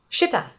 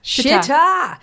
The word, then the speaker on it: Shita: Is it spelled like cheetah? – No. – Okay. – S-H-I-T-A. – Shitta. – Shitta. – Much better.